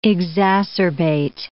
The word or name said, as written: exacerbate